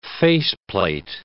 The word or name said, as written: faceplate